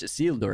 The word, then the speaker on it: Isildur: True Isildur, sorry. What do I keep saying? – Isildur. – Isildur. Isildur. – Say it like it's Spanish. Isildur. – Isildur. Oh, that's better now.